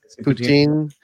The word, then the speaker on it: poutine: poutine, sorry, in French, but, you know, it doesn't matter.